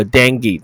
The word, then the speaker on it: dinghy: So Chinese man indicted after selling illegally to Taiwan on Ding，这个字又是一个不发音，好不好？OK 哈，这个呃 indict C 不发音，这个字是 H 不发音，G H 的 H 不发音，所以这个字怎么念呢？So dingy，dingy。 OK，有点像登格勒那个 dinghy，登登格勒是 dinghy，这是 dinghy，dinghy 就是小游艇，所以特别啦，找来这两个字很棒，对不对？